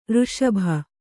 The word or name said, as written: ಋಷಭ